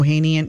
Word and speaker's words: Ohanian: And her husband, Alexis, um, how do you say his last name? – Richman. Oh- Ohanian. Yeah.